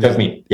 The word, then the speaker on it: Jagmeet: I'm sure uh, Jagmeet is it Jagmeet Singh? – Um, Jagmeet, yeah.